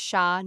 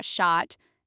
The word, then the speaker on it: chat: Final consonants like T, S, D, and X are usually silent, so chat is pronounced sha, not shot.